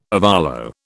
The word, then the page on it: ovolo: British English